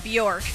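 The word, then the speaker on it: Björk: It rhymes with dork. – Bjork.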